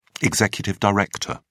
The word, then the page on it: executive director: British English